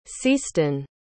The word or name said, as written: ceastun